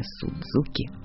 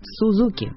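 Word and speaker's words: Suzuki: Так что произношение «Сузуки» от лукавого. «Сузуки» — вот так правильно.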